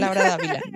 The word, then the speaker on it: Laura Davila: Laura Davila, yes.